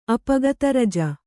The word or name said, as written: ಅಪಗತರಜ